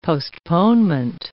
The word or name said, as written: postponement